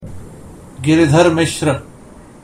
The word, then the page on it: Giridhar Mishra: Hindi